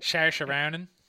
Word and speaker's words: Saoirse Ronan: Is there something almost distracting about how much better, I never say her name right, Cerise Ronan. – Saoirse Ronan.